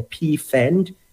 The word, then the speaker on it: Pfand: So in Germany, they have what we call a PFAND. It's like P with an F-A-N-D. It's like a fund, if you can pronounce it, without really breaking it down, because it's a German term.